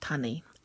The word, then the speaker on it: tangi: So words like mahunga, the word for head, that's spelled M-A-H-U-N-G-A, while it still is written M-A-H-U-N-G-A, is actually said mahuna, and that applies the same to tangi. Looks like tangi, T-A-N-G-I, but is actually said tani.